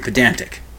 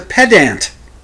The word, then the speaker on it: pedant: And he looks at me, and I looked at him and said, "Pedant." That's the pedantic moment. It's pronounced pedant, not pedant. It's pedantry. No, it's not. It's pedantry, it's pedantic, but it's pedant.